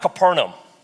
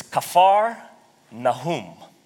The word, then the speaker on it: Kfar Nahum: It's not Capernaum. It's Kfar Nahum.